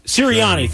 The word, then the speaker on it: Sirianni: And also pointing out that what's your coach, Spiriano? Am I saying that right, Sirianni? – Nick Sirianni? – Sirianni. Thank you.